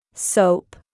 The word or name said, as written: Soap